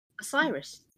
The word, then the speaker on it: Osiris: Number six, The Messiah, which is based on Osiris. Oh, is that how you pronounce it? – Osiris, yeah.